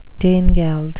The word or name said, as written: danegeld